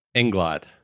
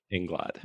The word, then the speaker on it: Inglot: Tell me again the correct pronunciation of your last name. – Inglot. So it's Matt Inglot.